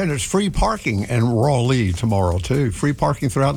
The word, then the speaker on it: Raleigh: Hey, at WPTF back in the day when they'd hire a new announcer, first thing they addressed was the pronunciation of what they call Raleigh, R-A-W hyphen L-E-E.